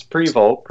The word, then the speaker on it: Prevost: It's pretty close. It's Prevost. Pretty close. – Prevost. No S. All right. Prevost. Yeah. Cody Prevost. – S and the T is silent.